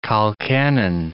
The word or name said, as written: colcannon